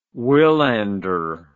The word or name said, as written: wilander